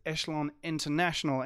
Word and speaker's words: Echelon International: We'll be interviewing him on his own unit, which is Echelon International. Am I pronouncing that correctly? – That's right.